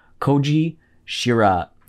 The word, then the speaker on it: Kōji Shiraishi: And today we're talking about Noroi the Curse, which is a 2005 film directed by Kōji Shiraishi.